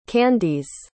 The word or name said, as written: candis